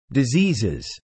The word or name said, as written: diseases